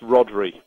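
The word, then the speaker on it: Rhodri: It's pronounced Rodri.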